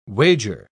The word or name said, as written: wager